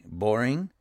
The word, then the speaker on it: Boreing: It says, Daily Wire co-founders Ben Shapiro and Jeremy Boring, I think that's how you say his name, it's B-O-R-E-I-N-G, Boring.